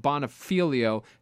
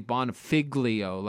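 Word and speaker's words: Bonfiglio: Check him out, R-O-B-B-O-N-F-I-G-L-I-O, Rob Bonifilio. The G is silent. A lot of people say Bonifiglio, like a fig leaf, but it's not. It's Rob Bonifilio.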